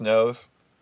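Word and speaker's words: nóż: The Polanski, cut you like a fish, when he slices Jack Nicholson's nose. – There's a couple things about that. Well, you know Polanski's film Knife in the Water. In Polish, the word knife is pronounced nos.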